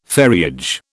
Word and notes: ferriage: British English

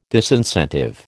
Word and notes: disincentive: American English

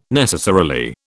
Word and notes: necessarily: British English

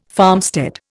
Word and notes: farmstead: Indian

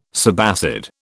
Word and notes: subacid: British English

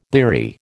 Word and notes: bleary: American English

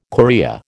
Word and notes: chorea: American English

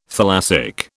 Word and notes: thalassic: British English

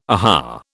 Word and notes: aha: British English

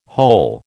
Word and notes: hole: American English

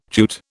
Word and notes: Jute: British English

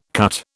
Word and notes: cut: British English

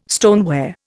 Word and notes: stoneware: Indian

